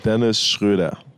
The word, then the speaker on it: Dennis Schröder: Dennis Schröder.